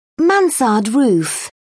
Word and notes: mansard roof: British English

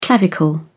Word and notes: clavicle: British English

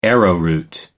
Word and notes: arrowroot: American English

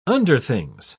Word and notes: underthings: British English

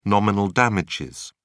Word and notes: nominal damages: British English

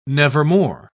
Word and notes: nevermore: British English

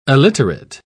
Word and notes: aliterate: British English

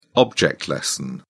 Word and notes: object-lesson: British English

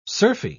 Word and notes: surfy: British English

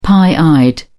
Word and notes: pie-eyed: British English

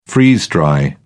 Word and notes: freeze-dry: British English

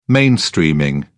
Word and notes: mainstreaming: British English

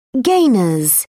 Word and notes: gainer: British English

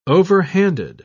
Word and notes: overhanded: British English

